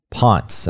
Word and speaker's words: Ponce: But then there's businesses along Ponce, as it's pronounced. It's not the correct pronunciation of the man's name, but that's the way they pronounce it in Atlanta.